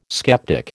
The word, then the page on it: sceptic: American English